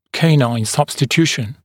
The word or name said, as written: canine substitution